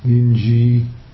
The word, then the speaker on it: Linji: Linji is Chinese, or my poor attempt to pronounce the Chinese. And Linji in Japanese is pronounced Rinzai.